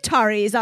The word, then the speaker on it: Ataris: And spoiler, it's the one with the Ataris on it. Is that how you say it? – Yeah.